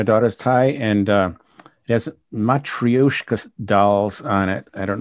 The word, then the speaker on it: Matryoshka: Matryoshka, I believe, is how it's pronounced. Matryoshka. Mm-hmm. Although I could be leaving out a syllable.